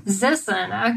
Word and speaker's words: Zisson: And my guests tonight are David Nissen. Did I say that right, David? – Zissen with a Z. – Zissen, okay.